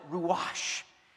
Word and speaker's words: ruach: First of all, in the Hebrew language, the word for spirit, when you pronounce it, ruach, you have to say it with breath coming out of your mouth.